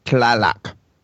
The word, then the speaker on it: Tlaloc: No, that was the text on the warlock weapon, the Tlaloc. – Tlaloc. You put the emphasis on the wrong syllable. Tlaloc. Tlaloc.